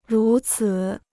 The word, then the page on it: 如此: rú cǐ